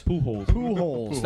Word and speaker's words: Pujols: Pujols.